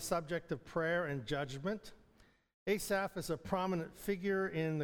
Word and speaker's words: Asaph: How do you pronounce it? Go Asaph. So that's what it is, Asaph.